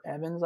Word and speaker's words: Evans: But the one that I've been active on here more recently is Topher Evans, I believe.